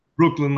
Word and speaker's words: Breukelen: Brooklyn, like the bridge in the borough.